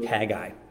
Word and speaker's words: Haggai: And as I was watching some videos in regard to that seminary class, the instructor pronounced this book Haggai. So that's what I'm going with. If you say Haggai because you learned it from a Awana song or your pastor used to say it or you say it that way, great, run with it. We're not going to debate that. I'm not going to discourage you. We're not going to get in a fight about it. I just pronounce it that way because that's the way I was taught.